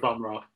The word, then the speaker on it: Bumrah: Like, you know, like, and did you notice when you were watching that cricket game, they were just like, it was Bumrah. They kept mispronouncing his name. So what is it? I would like to think it's Bumrah. – I would go with Bumrah, yeah.